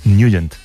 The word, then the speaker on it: Nugent: Siempre lo hemos llamado en el barrio Ted Nugent o Ted Nugent. – Claro, yo era de Ted Nugent. – Pero la pronunciación correcta es Nugent.